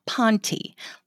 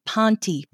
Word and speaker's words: Ponte: It is spelled P-O-N-T-E and it is pronounced Ponte. You do not have pont pants. You do not have point pants. You do not have pointy pants.